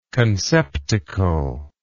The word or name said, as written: conceptacular